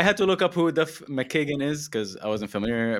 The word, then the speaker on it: Duff McKagan: I mean, could you say his name please, because I don't want to butcher it. – Duff McKagan. – McKagan. All right. Yeah. Duff McKagan.